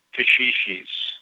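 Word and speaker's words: caxixi: C-A-X-I. How do you say that? – Kashishis. – Kashishi. Oh, my God.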